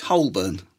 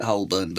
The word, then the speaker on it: Holborn: Is Holborn.